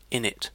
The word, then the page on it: init: British English